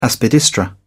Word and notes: aspidistra: British English

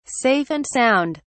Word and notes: safe and sound: British English

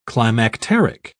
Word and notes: climacteric: British English

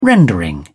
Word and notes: rendering: British English

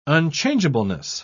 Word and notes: unchangeableness: British English

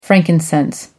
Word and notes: frankincense: American English

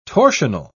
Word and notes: torsional: British English

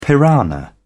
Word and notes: piranha: British English

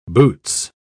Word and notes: boots: British English

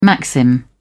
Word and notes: maxim: British English

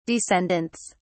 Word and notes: descendants: British English

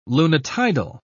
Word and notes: lunitidal: British English

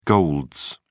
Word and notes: golds: British English

